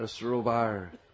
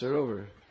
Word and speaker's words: sarovar: A sarobar, as Maharaj likes to say. Our Western tongues butcher all the nice Brijbhasa words. It's sarovar. It's a short A.